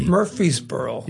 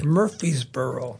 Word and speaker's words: Murfreesboro: And I think it's Murfreesboro, Tennessee. – Murfreesboro. – Yeah, I think that's how you say it. – Murfreesboro.